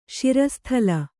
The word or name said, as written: ಶಿರಸ್ಥಲ